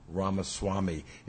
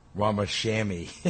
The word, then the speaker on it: Ramaswamy: So, by the way, the guys at the studio called me and said, you mispronounce Ramaswamy. It's Ramashammy.